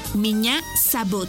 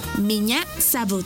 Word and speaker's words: Меня зовут: Меня зовут. Por ejemplo, Меня зовут. Esta palabra se escribe Меня. M. I. Ñ. A. Меня. Зовут. Z. A. V. U. T. Зовут.